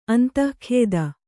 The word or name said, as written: ಅಂದಗಾತಿ